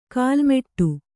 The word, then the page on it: ಕಾಲ್ಮೆಟ್ಟು: kālmeṭṭu